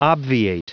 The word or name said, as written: obviate